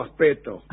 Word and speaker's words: aspecto: La verdad, qué lindo aspecto. Trate de pronunciar la C.